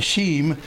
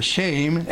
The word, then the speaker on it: Shem: Actually, the Hebrew would be Shem or Shame, S-H-E-M, but it's a long E pronounced A in the Hebrew.